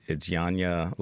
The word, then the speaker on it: Janja: No, the first name is Janja. – Janja. – I butchered it all. My apologies. I usually get a chance, but we were kind of late. So it's Janja Lelich? – That's right.